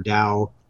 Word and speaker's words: Tao: That's spelled T-A-O, but it can be pronounced Tao or Dao. I think Dao is more common.